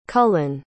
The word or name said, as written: cullan